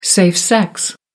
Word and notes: safe sex: American English

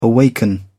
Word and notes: awaken: British English

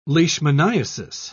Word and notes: leishmaniasis: British English

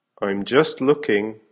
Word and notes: I'm just looking: American English

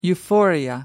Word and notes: euphoria: American English

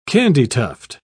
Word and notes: candytuft: British English

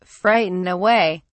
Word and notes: frighten away: British English

